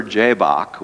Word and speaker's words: Jabbok: So the dramatic encounter happens at the river Jabbok, which rhymes with Jacob.